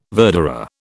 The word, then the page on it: verderer: British English